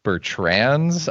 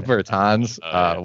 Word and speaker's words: Bertans: I mean, right at the end, you said Bertrands, I think, instead of Bertans, which I kind of enjoyed.